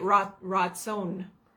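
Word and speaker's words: ratzon (רצון): And that comes from, that's the Hebrew word, it's you say it ratzon, is how you say it. – It sounds like rat. – Ratzon. R-A-W. Ratzon. Like you, Ratzon.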